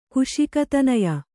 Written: ಕುಶಿಕತನಯ

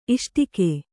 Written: ಇಷ್ಟಕೆ

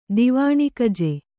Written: ದಿವಾಣಿಕಜೆ

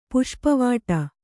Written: ಪುಷ್ಪವಾಟಿ

